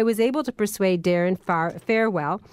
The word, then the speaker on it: Farwell: I say farewell because I believe that it's an apropos thing for someone dealing in money if you can farewell. But I will say it so you can spell it properly. Farwell, F-A-R-W-E-L-L.